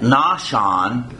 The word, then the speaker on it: Nahshon: And Phares begat Esram, and Esram begat Aram, and Aram begat Minadab, And Aminadab begat Nahshon. That's a Hebrew pronunciation because that's the one I know.